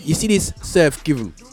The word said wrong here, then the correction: Surf GVNG: yeah, is that how you say it, Mike? – I thought it was Surf Gang. – Oh, it's got GVNG. What does that mean? Surf Gang. – I'd probably say gang, you know.